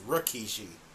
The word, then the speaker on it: Rikishi: It's Rikishi.